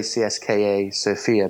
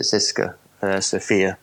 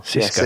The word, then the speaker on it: CSKA Sofia: Well, I always say CSKA Sofia, but apparently you pronounce it Siska Sofia. So he, apparently he's going to join. So what's that, sorry? – Like the thong song. – Cisco. Yeah, like Cisco, yeah.